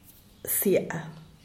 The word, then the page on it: siab: diaz_ordaz